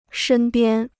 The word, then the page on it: 身边: shēn biān